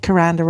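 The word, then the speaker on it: Kuranda: Yeah, Caranda rhymes with veranda.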